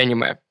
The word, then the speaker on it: аниме: Аниме или аниме – это тоже большой вопрос, но совершенно без разницы, где вы ставите ударение, потому что в японском ударение плавающее. Ну, они говорят как-то ближе к аниме, но можно вполне говорить аниме, потому что в русский язык уже благополучно вошло.